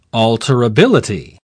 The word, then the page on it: alterability: British English